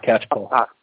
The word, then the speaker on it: Catchpole: His name is James Catchpole. Is that the right way to pronounce it, James? – That's right, yes, Catchpole.